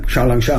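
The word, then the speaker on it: 小龙虾: Xiaolongxia.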